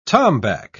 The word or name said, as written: tombac